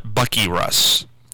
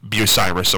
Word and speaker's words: Bucyrus: don't call it Bucky Russ, Bucyrus, of course.